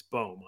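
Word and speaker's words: Bohm: I always say bomb. It's boom. I, I actually looked it up last week because I didn't know how to say it, but that Phillies lineup is absurdly good.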